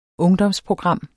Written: ungdomsprogram

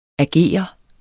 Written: ager

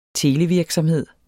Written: televirksomhed

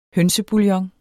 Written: hønsebouillon

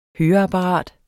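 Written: HØREAPPARAT

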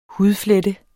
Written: hudflette

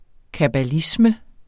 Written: kabbalisme

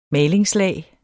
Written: malingslag

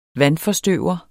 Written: vandforstøver

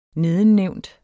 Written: nedennævnt

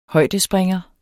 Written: højdespringer

